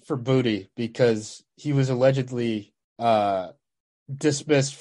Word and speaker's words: Boutte: So also, I don't think it's Boutte. I think it is pronounced Booty. – Is it Booty? – It is pronounced. I looked it up. I'm pretty sure, like 90% sure it's Booty.